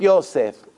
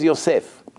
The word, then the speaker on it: Yosef: Joseph, Yosef. Okay, no problem. It's a biblical name. So the goyim pronounce it a little bit different. But for you, is Yosef.